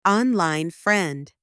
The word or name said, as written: online friend